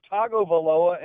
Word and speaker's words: Tagovailoa: It's Tagovailoa, and they've been pronounced.